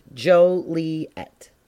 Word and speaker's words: Joliet: It's pronounced Joliet. And you are to say it right. The founding fathers of Joliet were sick and tired of visitors mispronouncing the city's name as Joliet.